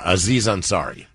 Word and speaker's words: Aziz Ansari: Yeah, Aziz Ansari.